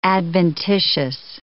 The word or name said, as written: adventitious